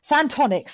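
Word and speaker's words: Santonix: I'm talking, they basically, he has this, as they mentioned in the synopsis, he has an architect friend called Sanatonics. How's that for a name? – Wow. – Santonics, sorry, I almost said it wrong.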